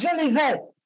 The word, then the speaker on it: Jolivet: You know, they'd call the station and say, her name is Jolivet. She's saying it wrong.